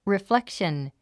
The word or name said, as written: reflection